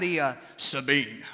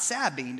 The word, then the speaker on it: Sabine: We went down the Sabine, all right? That's how they say it around here, the Sabine. Not Sabine, the Sabine.